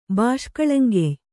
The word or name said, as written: ಬಾಷ್ಕಳಂಗೆಯ್